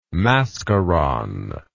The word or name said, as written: mascaron